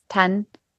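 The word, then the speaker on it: Thandiwe: Is it Thwandu Newton? – No, it's T-H like Thomas. Tandwe Newton. Or like Tandy. Some people call it Tandy, but Tandwe Newton.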